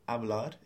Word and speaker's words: hablar: So hablar is spelt H-A-B-L-A-R. The H, as with almost all H's in Spanish, is silent.